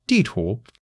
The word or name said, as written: di4 tu2